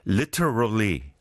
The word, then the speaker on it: literally: pronounce T as in T, like the British style. I think it's better. Literally.